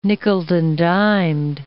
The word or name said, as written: nickeled-and-dimed